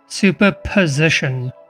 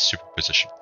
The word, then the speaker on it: superposition: So superstition, I might have butchered that word, sorry guys, basically means that... – Superposition, that's it, thank you. Yes, superposition.